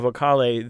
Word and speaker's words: Vocale: So the Vocale, is that how you say it? – Yes, Concerto Urbano Vocale.